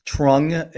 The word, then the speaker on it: Trung: Trung is not, but the last name Nguyen is a common last name.